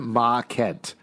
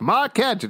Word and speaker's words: Mahkent: And the crazy name that he has in the comic books is Jordan Ma Kent. It's spelled M-A-H-K-E-N-T.